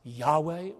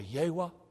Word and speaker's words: Yahweh: Often pronounced Yahweh or Yehwah.